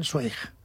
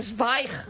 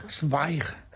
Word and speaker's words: Zweig: Tú sabes que yo toda mi vida he estado diciendo que yo leía Stephen Zweig. – Ah, claro. Es no. Zweig. – Zweig. – Zweig.